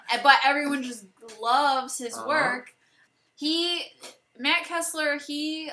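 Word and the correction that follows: Keeslar: Kiesler? – Yeah.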